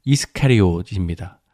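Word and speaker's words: Iscariot: 적지 않은 학자들의 견해인데 가룟의 본래 발음이요. 이스카리옷입니다. 가룟과 좀 다른 발음이지요.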